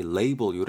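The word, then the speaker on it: label: 지금도 이렇게 유, 의해 두셔야 될 게 라벨이라고 우리가 알고 있던 것이 원래는 발음이 레이블 요래된다라는 거 잘 챙겨 두시면 좋을 것 같습니다.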